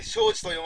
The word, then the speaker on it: 正嗣: はは、正しいに次と書いて庄司と読ませるとはハイカラですな。